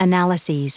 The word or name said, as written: analyses